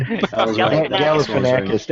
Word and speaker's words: Galifianakis: But he was surprisingly really good here, and that's credit to Zach Galifianakis, how do you say his last name? – Galifianakis, that's good.